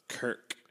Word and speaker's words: Kirk: Kirk. Like Captain Kirk. Except dumber.